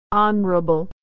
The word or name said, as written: honorable